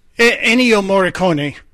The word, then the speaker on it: Ennio Morricone: Ennio Morricone? Is that it? – Ennio Morricone. – Okay, yeah.